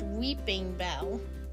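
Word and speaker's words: Weepinbell: Weeping Bell, you would think it would be spelled weeping, as in crying, but it's Weepin Bell, but it's pronounced Weeping Bell.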